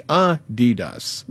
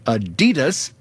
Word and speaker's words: Adidas: It's supposed to be Adidas, with a slight emphasis on the first syllable, not Adidas, stressing the second.